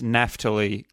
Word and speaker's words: Naphtali: So just as a heads up, I'm going to be pronouncing it as Naphtali, closer to the proper Hebrew pronunciation.